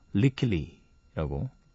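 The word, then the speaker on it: Lykke Li: L Y K K E 그리고 L I 리킬리라고 음, 재밌는 이름이네요.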